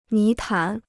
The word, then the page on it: 泥潭: ní tán